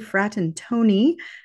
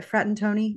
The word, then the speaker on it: Fratantoni: Today, I am speaking with Dr. Julie Fratantoni hope I said that right, Frat and Tony.